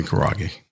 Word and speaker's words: karaage: Chicken karaage.